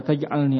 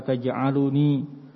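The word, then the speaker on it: taj'alni (تجعلني): Nah, kalau kamu bilang Rabbi la taj'alni asqa halqik, ini sahih. Tapi kalau kamu bilang lau qulta Rabbi la taj'aluni asqa halqik, maunya berdoa. Tapi dia bicara bacanya Rabbi la taj'aluni asqa halqik, ini keliru. Salah ngomong.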